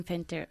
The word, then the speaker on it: center: Shopping center. – ¿Y cómo se pronuncia? – Shopping center. – ¿Y cómo se pronuncia? Center. – Center. – Center, como si empezara con una S.